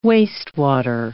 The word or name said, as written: wastewater